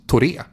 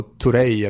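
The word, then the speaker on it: Touré: Padembo var en sån som jag i alla fall tror skulle hans efternamn uttalas Toré. Men han blir alltid då Torei eller Torai.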